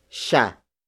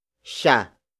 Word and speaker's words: siya: Which is spelled as T-S-I-N-O-Y. The next word is sha. Sha. Sha. Sha is spelled as S-I-Y-A.